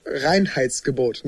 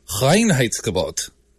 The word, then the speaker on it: Reinheitsgebot: So yeah, I'm sorry about mispronouncing Reinheitsgebot. Apparently it's Reinheitsgebot. I think that's right.